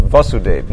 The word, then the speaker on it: Vasudev: He's the son of Vasudev. Notice that the long A is very significant.